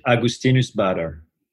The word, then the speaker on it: Augustinus Bader: So I will pronounce it Augustinus Bader. That's my way, but I'm French again. So, you know, he's German. So I guess everyone, you know, it's good. Everyone can take ownership of the way to pronounce it.